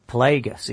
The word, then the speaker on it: Plagueis: But this Darth Plagueis book. – Plagueis. – Is that how you pronounce it? Plagueis. I can't remember. Are you sure that's how you pronounce it? – 100%.